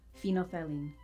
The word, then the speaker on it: phenolphthalein: Phenylphthalein. Phenylphthalein. Phenylphthalein. Phenylphthalein. – No. The PH is silent. – Which one? – Right, second PH. – Ah. – So it's phenylphthalein? – Phenylphthalein, yes.